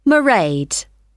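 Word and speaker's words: Mairead: It's M-A-I-R-E-A-D. Maraid.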